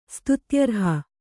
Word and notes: ಸ್ತುತ್ಯರ್ಹ: kannada

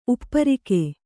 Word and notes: ಉಪ್ಪರಿಕೆ: upparike

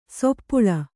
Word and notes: ಸಪ್ಪಳು: sappaḷu

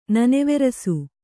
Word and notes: ನನೆವೆರಸು: naneverasu

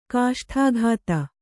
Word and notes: ಕಾಷ್ಠಾಘಾತ: Kannada

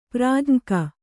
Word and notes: ಪ್ರಾಜ್ಞಕ: Kannada

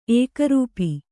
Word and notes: ಏಕರೂಪಿ: Kannada; ēkarūpi

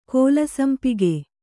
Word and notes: ಕಲ್ಲುಸಂಪಿಗೆ: kallusampige

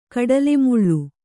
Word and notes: ಕಡ್ಲೆಮುಳ್ಳು: Kannada

